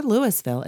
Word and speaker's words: Louisville: You don't say Louisville or Louisville. It's Louisville. And you know how I was taught to say that? – How? – You got to say it like you got a piece of shit in your mouth.